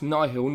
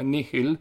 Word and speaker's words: Nihil: I found out that I mispronounced it during the chat. It's Nihil, not Nihil.